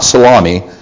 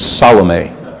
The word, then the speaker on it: Salome: It's not salami, it's Salome.